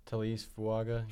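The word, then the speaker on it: Taliese Fuaga: Talese Fuaga.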